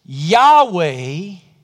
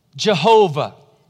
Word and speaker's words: Yahweh: David declares, "Oh, Lord," the first Hebrew word there is the word that you and I would pronounce either one of two ways, Yahweh or Jehovah. Either of those are English pronunciations of this Hebrew word.